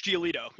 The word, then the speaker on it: Giolito: Lucas Giolito.